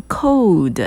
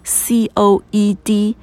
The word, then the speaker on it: co-ed: Co-education，我们讲男女合校，那这个字因为念起来好长啊哈，所以他们口语当中哈会比较倾向于讲 co-ed，co-ed，注意哦，他们不会念 code，哎，你不要下次看到 c-o-e-d。就把它念成 code 哈，c o e d 这个字要把它还是要拆成两个字来念 co-ed，co-ed。